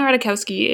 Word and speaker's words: Ratajkowski: I don't think you pronounce the J, which makes it helpful. Anyway, so Emily Ratajkowski, if you do not know, is a very famous model, known mostly for being incredibly sexy.